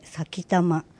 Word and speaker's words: 埼玉: 最初の先玉は、県名の埼玉と書いて先玉。